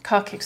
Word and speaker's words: Kharkiv: The last volunteer to leave Kharkiv. Is that right? Is that the right pronunciation? Kharkiv. – Kharkiv, sorry.